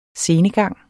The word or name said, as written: scenegang